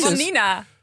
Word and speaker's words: Vanina: Nee, Bavina, wat zeg je? Het is niet zo moeilijk, toch? – Nee, Vanina. – Ja, maar je zegt met een beetje met een B/W. – Vanina. – Ja, dat is toch? Zoals sommige mensen Barcelona ook uitspreken.